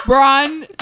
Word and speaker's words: LeBron: Wow. LeBron. Two syllables. Got it.